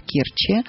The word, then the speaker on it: Керчи: В частности, я должна извиниться, потому что в Керчи нужно говорить правильно, ударение ставить именно так. Керчь в Керчи.